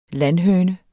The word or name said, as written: landhøne